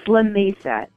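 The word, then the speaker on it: Lamesa: Is it La Mesa or La Mesa? – It's La Mesa.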